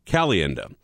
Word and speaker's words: Caliendo: Is it Caliendo? – Caliendo.